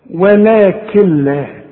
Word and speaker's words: ولكن لا: كل نون بعدها لام ما تنطقهاش عند الوصل، اشبك الكاف مع اللام، شوف، ولكن لا، وكأن النون غير موجودة.